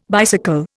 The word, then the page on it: bicycle: Indian